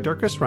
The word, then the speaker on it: Durkis: Sarah Durkis, Rhymes with Circus, Kelly Cameron, Wayne Pedersen, Janet Selby.